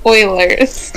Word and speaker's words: Eulers: Yes, she used to be the host of the podcast Edmonton Eulers, but it's pronounced Oilers.